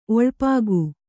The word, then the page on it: ಒಳಪುಗು: Kannada